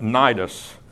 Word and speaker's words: Cnidus: The ship did eventually arrive at Cnidus. Seems funny to pronounce something Cnidus that begins with a C, but that's apparently what's available to us here in terms of pronunciation.